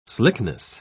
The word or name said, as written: slickness